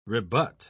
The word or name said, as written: rebut